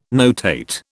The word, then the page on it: notate: British English